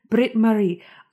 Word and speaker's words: Britt Marie: Britt Marie.